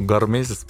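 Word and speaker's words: гормезис: Гармезис, сон, еда, э, стресс-менеджмент. – Слушай, я э, гармезис, правильно произношу? – Гармезис, да.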